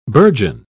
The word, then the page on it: bourgeon: British English